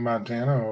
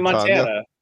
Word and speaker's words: Montana: It's Tony Montana. – Yeah. – It's not like the state.